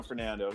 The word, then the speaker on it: Fernando: Did I say that right? – Oh yeah, Bruno Fernando.